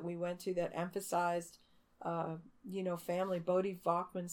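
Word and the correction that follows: Voddie Baucham: spoke at one. Is that the way? I, did I say his name right? – I think it's Voddie. Voddie Baucham.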